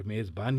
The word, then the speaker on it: میزبان: چنانچہ ایران والی یائے معروف کا چلن یہاں نہیں ہو سکا اور ہمارے تلفظ کے مطابق یہ لفظ میز اور میزبان ہی بولے جاتے ہیں،